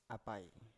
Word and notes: apai: Biak

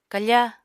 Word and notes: kafa: apatani

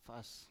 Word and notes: fas: Biak

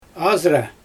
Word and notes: azra: Xyzyl

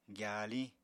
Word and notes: gyapya-gyasa: apatani

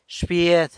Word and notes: sa bied: aunt (poss. form)

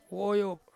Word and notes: woyop: Ansus

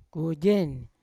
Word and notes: koigen: Yawa

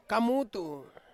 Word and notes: kamutu: Ansus

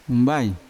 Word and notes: umbai: Yawa

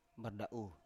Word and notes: barda7u: Biak

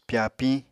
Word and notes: pyapiñ: Apatani

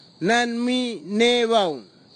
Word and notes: nanmi nevau: Kwamera

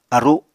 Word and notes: aru: Apatani

